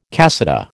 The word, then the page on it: cassata: American English